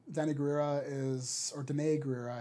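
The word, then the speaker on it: Danai Gurira: Danny Gurira is rumored. Danae Gurira, I think is how you say it.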